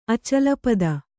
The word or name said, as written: ಅಚಲಪದ